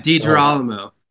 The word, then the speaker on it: DiGerolamo: DIGEROLAMO.